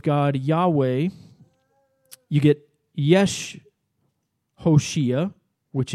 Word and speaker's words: Hoshea: And Joshua was originally pronounced Hoshea, and it means salvation.